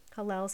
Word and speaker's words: Hallel: Hallel sounds like hallelujah because it's spelled H-A-L-L-E-L.